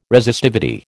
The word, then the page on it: Resistivity: American English